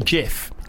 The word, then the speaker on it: GIF: Right, so you know what we call a GIF? – Yeah. – Did you know that it's actually pronounced Jif? It's meant to be pronounced Jif.